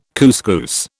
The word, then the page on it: couscous: British English